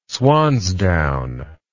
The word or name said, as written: swansdown